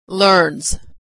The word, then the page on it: learns: ending z